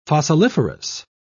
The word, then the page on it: fossiliferous: British English